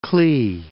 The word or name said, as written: Clee Hills